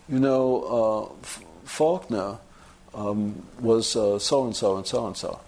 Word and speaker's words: Faulkner: Which he pronounces Faulkner. I called it Falconer, being, right.